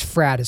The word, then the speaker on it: Fradd: So I want to play this clip from Dennis Prager's interview when he was on Pints with Aquinas with Matt I think it's Frad is how you say his last name, F R A D D.